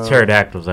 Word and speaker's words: pterodactyl: Since the pterodactyl's over, it doesn't start with a T, does it? – No.